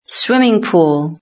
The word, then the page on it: swimming pool: British English